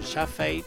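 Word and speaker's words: Chaffey: Es una coproducción inglesa-estadounidense dirigida por Don Jaffe, o Jaffey, como se pronuncia.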